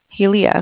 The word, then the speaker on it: Hialeah: Is it Hialeah? – Hialeah, okay.